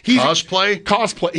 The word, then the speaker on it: cosplay: Is cosplay? How do you say that? Like the cosplay.